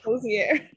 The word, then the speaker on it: Hozier: Hozier.